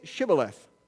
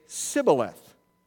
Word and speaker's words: Shibboleth: And if he said no, they would say to him, "Now say Shibboleth." But he would say Sibboleth, for he could not pronounce it correctly.